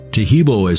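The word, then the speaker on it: Taheebo: Taheb is spelled T-A-H-E-E-B, like boy, O, then the word tea, and then the word club.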